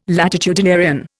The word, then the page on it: latitudinarian: Indian